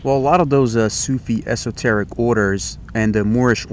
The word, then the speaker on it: Sufi: About Sufi origins of Freemasonry.